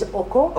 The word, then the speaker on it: Oko: I wondered that, because I did Google Oko and it came up with the right pronunciation is Oko.